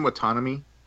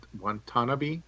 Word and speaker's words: Watanabe: Ken Watanabe. – Watanabe. – Saying the name wrong. Thank you, sir.